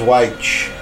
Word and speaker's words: Wytch: The Quill with Master of the Skies, Witch, spelled W-Y-T-C-H, with Evil Heart, and Snorlax, Merchants of Opulence. – Witch. Wait a minute, W-Y-T-C-H? Wouldn't that be pronounced Weich? – Witch. – Weich! Weich! – It was Weich.